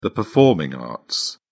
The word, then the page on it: performing arts: British English